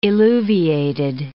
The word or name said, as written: illuviated